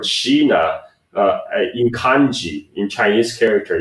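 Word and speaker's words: "shina," (支那): In Japanese, it's pronounced "shina," right?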